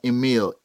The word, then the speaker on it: Emile: Yeah, so it's Jonathan, J-O-N-A-T-H-A-N, and then Emil, E-M-I-L-E.